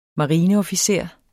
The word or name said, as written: marineofficer